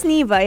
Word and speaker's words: Neeva: And it's not spelled the same way. So Nivea is spelled N-I-E-V-A. This one is N-E-E-V-A. So it's Nivea, yeah.